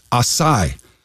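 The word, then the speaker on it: açaí: A-C-A-I. Maybe you see that. A lot of people mispronounce that as acai. But I didn't know this. It's acai.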